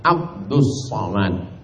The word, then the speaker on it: Abdul Somad: Tulisannya Abdul Somad, tapi membacanya adalah Abdus Somad.